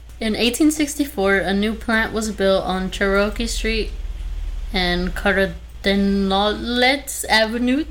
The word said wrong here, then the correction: Cherokee: Also, Cherokee? – Did I say that wrong? – You said Cherokee. I thought you were going to say churro.